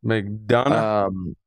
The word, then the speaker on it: McDonagh: I want him to work with Martin McDonagh. I think that's his name, right? Did I say that right? – McDonagh.